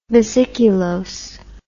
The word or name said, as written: vesiculose